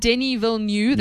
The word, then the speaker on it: Denis Villeneuve: His name's Denis Villeneuve.